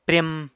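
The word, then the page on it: pryem: San Lucas Quiaviní Zapotec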